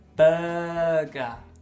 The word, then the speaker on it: burger: Está, mucha gente lo dice mal. – Yeah. No se dice burger. Burger. burger. Se dice burger.